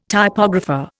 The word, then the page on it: typographer: Indian